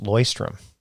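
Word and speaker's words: Leuchtturm: By the way, I learned that it's pronounced Loystrom.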